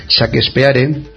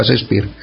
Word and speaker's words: Shakespeare: empezó a hablar de Shakespeare, y lo pronunció así, y un alumno pues se apresuró a corregirle diciendo que se pronunciaba Shakespeare,